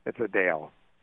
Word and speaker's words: Adele: How do we pronounce her name now? Adele? – Adele. – Adele, okay. – Or is that, it's not Adele? – Adele. – You're the music expert. – Adele. It's Adele. And so she is complaining.